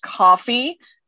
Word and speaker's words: Ko-fi: Yeah, I do have, I think it's pronounced coffee, spelled K-O-F-I.